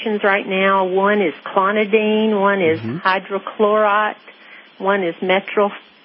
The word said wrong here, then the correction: metoprolol: I can't pronounce it. Metoprolol. – It's called metoprolol.